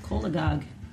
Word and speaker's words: cholagogue: Yeah, kolagog.